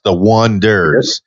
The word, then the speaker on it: Oneders: And they had a band called The Wonders, and they wanted to spell it O-N-E-D-E-R-S, The Wonders. And it looked like O-Needers. O-Needers.